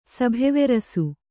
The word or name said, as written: ಸಭೆವೆರಸು